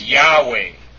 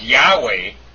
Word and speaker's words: Yahweh: And so, the actual pronunciation of the sacred name, some people believe, actually, is really not known at all. And that we say it's Yahweh, Y-A-H-W-E-H. But that's putting vowels in there, which the ancient Hebrews didn't have.